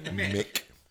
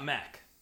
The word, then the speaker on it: McDonald's: So that's how it's pronounced. – Mick. – Not Mac.